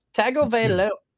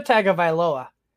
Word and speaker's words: Tagovailoa: Joe, you got Baker Mayfield against the Saints or Tua Tagovailoa. Oh man, I butchered it. Tua Tagovailoa at the Ravens.